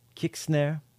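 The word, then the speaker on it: Kixnare: Yeah, so let's hear one by Kick Snare. Yeah, that's probably how you pronounce it, with an X, K-I-X-N-A-R-E. Digital Garden is the album, and the song is Rising Sun.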